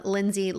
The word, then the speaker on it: Lindsay: Find me at I am Lindsay Lockett.